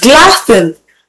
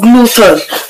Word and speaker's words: glutton: The word is pronounced glutton. Glutton and not gluten. So don't let the letter U in the spelling confuse you to pronouncing the wrong word.